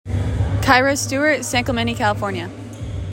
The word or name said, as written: Kyra Stewart